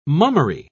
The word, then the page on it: mummery: British English